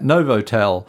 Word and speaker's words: Novotel: Novotel, mm-hmm.